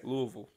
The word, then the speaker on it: Louisville: He's like, it's Louisville, but you got to say it like you're retarded. – I got to say it like you're slow.